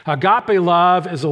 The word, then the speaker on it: agape: He uses the third word that's, that's most, most well known, agape, is the Greek pronunciation.